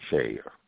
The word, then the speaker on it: Sidhe: No, look up the word shade, S-I-D-H-E. That's Irish pronunciation, shade. And these are dwellers beneath the earth, okay? And they're known to trick mankind. Huh? – You said A-I-D-E? S-H-E? – No, S-I-D-H-E. S-I-D-H-E. Someone, if you were to read it, would say city, you know, but it's pronounced Shay.